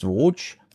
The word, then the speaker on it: Voge: Die Händler sagen, mir heißt Voge.